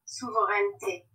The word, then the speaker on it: souveraineté: We would say souveraineté.